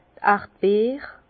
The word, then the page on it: d' Erdbeere: Bas Rhin; Herrlisheim